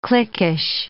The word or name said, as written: cliquish